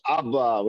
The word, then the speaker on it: Abba: What does Abba mean? Father. Father, Abba. Aleph, Bet Aleph. The second Aleph is silent. Abba.